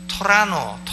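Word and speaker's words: Toronto: 근데 이 토론토는 미국 사람들은 토론토라고 발음하네요. 토라노, 토라노라고 발음합니다. 왜냐? N자 다음에 나오는 T자는 발음을 안 해요.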